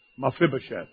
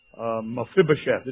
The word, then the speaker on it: Mephibosheth: Mephibosheth. Mephibosheth. And don't think I didn't practice that. Kind of refreshed my pronunciation a couple of times before I came here this morning. Mephibosheth.